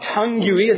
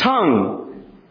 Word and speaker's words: tongue: Whenever you see a word ending with a U-E, the U-E is silent. So it's not vague-y, it's vague. It's not tongue-y, it's tongue."